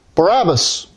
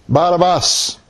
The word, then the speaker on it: Barabbas: Barabbas. Barabbas.